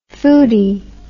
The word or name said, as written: foody